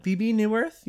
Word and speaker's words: Bebe Neuwirth: Is that how you pronounce her name? Oh, Bebe Neuwirth? Yes.